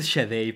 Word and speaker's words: Sade: nunca sabía cómo se pronuncia. Pues ¿eh? dicen que es Shade, pero no me quise ver tan mamado.